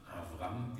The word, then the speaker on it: Avram: Abram, Avram, wie es in Hebräischen ausgesprochen wird, heißt der erhabene Vater.